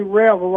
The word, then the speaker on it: Revel: You know, people pronounce my name different ways, and I just, you know, like it. Most of the English world will say Revel, R-E-V-E-L, and most of the French Canadian and the French and many of the others will say Revel.